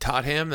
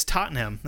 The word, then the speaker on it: Tottenham: Tot ham? That sounds like a Minnesota hot dish. – It's Tottenham. That's what I said. – I'm saying it wrong on purpose.